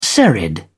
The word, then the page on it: serried: British English